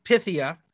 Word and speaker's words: Pythia: So we learn that Gia knows Pythia, as she says, and loves her so much. And this is when we get the confessional about how to pronounce her name, because Gia got it wrong. Pythia.